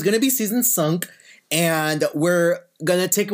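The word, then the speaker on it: cinq: And then starting January 1st is season, how do you say five in? – Sunk. – Sunk.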